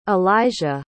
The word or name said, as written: alijah